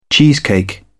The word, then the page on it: cheesecake: British English